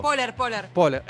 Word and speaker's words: Poehler: No puedo creer, Estaban abriendo para las Bikini Kill y Amy Poehler, no sé cómo se pronuncia, las vio. – Poehler.